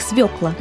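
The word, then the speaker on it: свёкла: Сегодня, вариабельные вы наши, мы разберемся с тем, как правильно произносить название такого овоща, как свекла. – Ребята, запомните раз и навсегда, это слово пишется с буквой Ё, поэтому ударение в нем всегда падает на первый слог.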